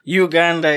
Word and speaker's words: Uganda: I actually talked about that, and I said, is it Uganda or Uganda? And he said, Uganda is French, is that right? – That's a more French intonation of the name. And Uganda is the way it's pronounced.